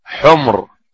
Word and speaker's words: حمر: حمر النعم. حمر بتسكين الميم، بتسكين الميم، حمر جمع حمراء، حمر النعم، النعم حمر النعم بفتح النون. وأما حمر، الحمر جمع حمار وينطق بحمر، حمر النعم يعني حمر الأنعام.